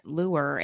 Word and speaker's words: Llewor: A-N-N-J-E-A. Mm-hmm. Morgan Lewer, and Lewer is L-L-E-W-O-R.